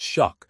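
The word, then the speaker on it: Shough: If their quarterback, Tyler Shuck, pronounced Shuck, develops into a franchise player, they will have the room to build around him.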